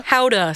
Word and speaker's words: howdah: Is it howdah? – Howdah, sorry.